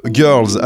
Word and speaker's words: Gyrls: Et là, Clément, tu vas nous parler de Girls. Alors, ça s'écrit G-Y-R-L-S.